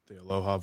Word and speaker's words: Aloha: A normal Aloha. You don't say it right, Jeff. Aloha.